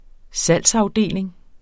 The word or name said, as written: salgsafdeling